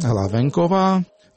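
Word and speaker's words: Hlavenková: Главенкова. Если сказать с чешским произношением.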